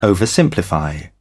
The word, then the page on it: oversimplify: British English